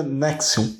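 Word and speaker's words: NXIVM: Pode não ser o mais conhecido assim, o caso NX e que se pronuncia Nexion.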